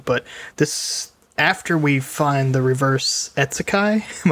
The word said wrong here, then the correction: Isekai: am I saying that right? – Isekai.